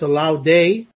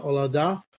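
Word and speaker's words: Olaudah: I always say this wrong. I think it's Olaudé, not Olauda.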